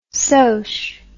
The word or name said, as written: sosh